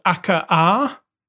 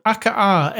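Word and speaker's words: Akkaarrh: I've been playing a new game this week called Akka-R. – Could you spell that for us all, please? – It's called Akka-R, A-K-K-A-A-R-R-H.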